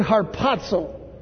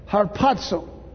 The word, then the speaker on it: harpazo: It's the Greek word harpazo. Harpazo. H-A-R-P-A-Z-O. If you're transliterating, pronounce harpazo.